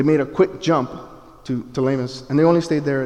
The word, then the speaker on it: Ptolemais: The P is silent there, and I really wanted to say the other word, Ptolemais, and I was like, nope, it's Ptolemais, where we greeted the brothers and sisters and stayed with them for a day.